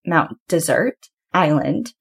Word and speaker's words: Mount Desert Island: And if you ever hear locals talking about it, they actually pronounce it Mount Desert Island.